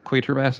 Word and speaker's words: Quatermass: I will call it the Quatermass experiment. Is that right? – Bam. Nailed it.